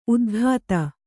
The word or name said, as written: ಉತ್ಖಾತ